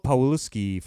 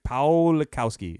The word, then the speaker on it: Pawlikowski: and Paweł Pawlikowski for Cold War. – I have... – Pawlikowski. It's a Polish last name. Pawlikowski, I think is how you say it.